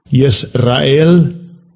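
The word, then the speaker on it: Yisrael (ישראל): Strong's H3478, Yisrael.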